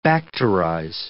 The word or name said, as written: bacterize